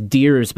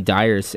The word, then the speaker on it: Deers: My last name is Deers, but people always call me Dyers, and I'm just like, yep, I don't care.